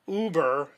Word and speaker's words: Uber: It's pronounced Uber, and it's spelled U-B-E-R.